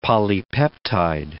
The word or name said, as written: polypeptide